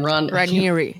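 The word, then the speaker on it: Raniere: Ranieri. Yeah, it doesn't look like it's going to be pronounced that way, but it is,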